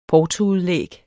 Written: portoudlæg

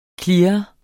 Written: CLEARENDE

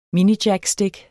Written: minijackstik

